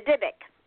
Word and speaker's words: Dybbuk: The Dybbuk.